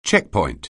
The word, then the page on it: checkpoint: English